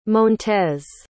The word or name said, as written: montez